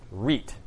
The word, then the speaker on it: REIT: So real estate investment trust, also abbreviated REIT, that's how you pronounce it, REIT.